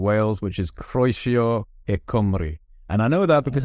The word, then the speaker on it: Cymru: Cymru. Cymru. – Cymru. – Really? Yeah, there's the it's Cym, like C-Y-M, so it's Cym. And then the R-U is, you got to roll the R, so it's Cymru.